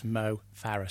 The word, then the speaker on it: Mo Farah: But it's only right to say his name correctly, so it's Mo Farah.